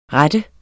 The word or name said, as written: rette